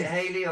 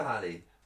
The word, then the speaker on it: Halley: Is it Halley or Halley? – Halley. Halley's coming, isn't it? I think there's a bit of a pronunciation difference between the Americans and the Brits on that.